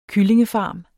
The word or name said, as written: kyllingefarm